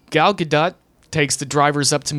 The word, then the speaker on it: Gadot: The correct pronunciation of her name is Gadot. G-U-H.